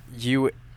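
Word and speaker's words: Eulalie: It's pronounced Ulawi. – Ulawi, Professor Ulawi.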